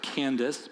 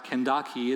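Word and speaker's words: Kandake: Your Bible may have a word that says, it looks like Candace, but it's pronounced Kandaki.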